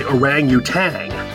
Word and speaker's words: orangutan: Most people say orangutan. They add the G at the end.